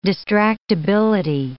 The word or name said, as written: distractibility